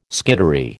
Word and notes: skittery: American English

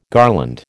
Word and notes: garland: American English

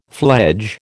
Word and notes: fledge: American English

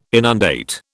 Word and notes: inundate: British English

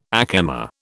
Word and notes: ack emma: British English

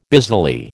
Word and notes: busily: American English

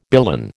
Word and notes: billon: American English

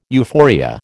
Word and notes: euphoria: American English

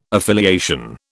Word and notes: affiliation: British English

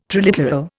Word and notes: triliteral: Indian

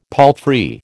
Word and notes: palfrey: American English

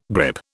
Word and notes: grippe: British English

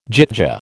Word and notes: gyttja: American English

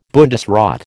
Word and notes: Bundesrat: American English

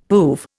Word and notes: poove: Indian

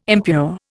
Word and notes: impure: Indian

